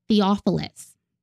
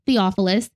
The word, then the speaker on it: Theophilus: And he was actually writing to one man named Theophilus. And I did say that correctly because, yes, I Googled to make sure I said that name correctly.